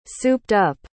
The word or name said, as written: souped-up